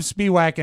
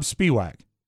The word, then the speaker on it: Spiewak: Well, it's the Mike O'Mara Show, but in studio right now, it's me, Rob Spiewak, and Oscar Santana. – Hold on a second. What did you say your name was, sir? – Sir, my name is Rob Spiewak. – Spiewak? Like from Washington, D.C.? – S-P-E. – Oh, my God. – W-A-K.